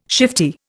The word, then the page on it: shifty: Indian